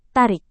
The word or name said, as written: tarik